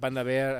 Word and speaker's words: Panda Bear: Després he rebut colleges per això i perquè no he pronunciat bé, perquè... Espanda Bear, no Bia. – Oh, clar, puta, clar, tio, clar. No... – Però, bueno, dit això, espero que Panda Bear